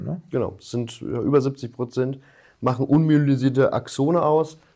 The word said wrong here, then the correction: unmyelinisiert: Das war ein ähm, bisschen genuschelt, unmyelinisiert. – Ja, unmyelinisierte Axone, ja. Bitte ein bisschen auf die Aussprache achten.